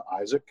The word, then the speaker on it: Isaac: It's Isaac, I-S-A-A-C, Arthur, if you can, never pronounce my own last name right, so I never know why I bother.